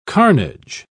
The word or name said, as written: carnage